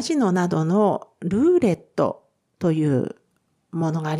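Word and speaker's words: roulette: ただしですね、このルーレットに関しては、ルーレットのレの部分は L ななので、フランス語発音ですと、ルーレット、ルーレットになります。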